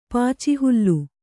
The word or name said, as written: ಪಾಚಿಹುಲ್ಲು